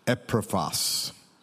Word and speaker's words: Epaphras: a pastor called Epaphras. I was pretty happy about that. I did research, I did Google, I did press the YouTube. This is how you pronounce that name.